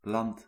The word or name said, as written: terrain